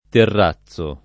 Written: terrazzare

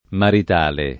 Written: maritale

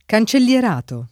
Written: cancellierato

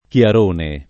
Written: Chiarone

